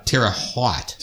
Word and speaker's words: Terre Haute: Is it Terre Haute or Terre Haute? – Terre Haute. Terre is so hot.